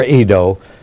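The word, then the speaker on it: eido (εἴδω): We would spell it like E-I-D-O, eido or edo, take your pick.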